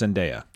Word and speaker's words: Zendaya: Yeah, and it's pronounced Zendaya.